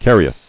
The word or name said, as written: Carious